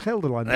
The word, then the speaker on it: Gelderland: Well it's a G. – Try and pronounce it properly, it's Gelderland.